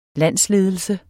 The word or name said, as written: landsledelse